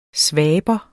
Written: svaber

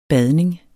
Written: badning

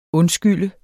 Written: undskyld

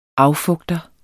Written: affugter

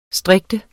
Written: strikte

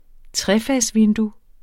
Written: trefagsvindue

